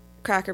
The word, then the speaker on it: craic: The term cracker has a, there is a idea about where the term comes from, and the actual etymology seems to come from the Irish word crack, which is spelled C-R-A-I-C, which means an entertaining conversation or like banter at the pub, that kind of thing.